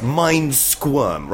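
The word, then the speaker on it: Mindsquirm: Well, okay, it is mind squirm. Right now, I'm going to one word.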